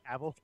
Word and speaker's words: Cavill: Cavill, even though you've been saying it, I'm like Henry Cavill's bad.